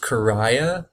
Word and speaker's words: Correia: I believe another person on the Zero Friends Art Collective is a guy by the name of Dave Karaya, I believe it's pronounced. It's at Dave, so D-A-V-E, and then last name is C-O-R-R-E-I-A on Instagram.